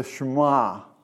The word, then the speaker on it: Shema: In this section, in chapter 6, let's see, starting out with verse 4, that section is called, if I pronounce it right, the Shema. Is that correct? – Yes, sir.